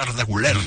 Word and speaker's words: Güler: De lo poquito bueno que tuvo el Real Madrid esta temporada fue Arda Güler, como se debe pronunciar, Arda Güler.